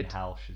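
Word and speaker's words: Greenhalgh: They are, everybody knows who these guys are, Stephen Greenhale. – Greenhalsh is how that's pronounced.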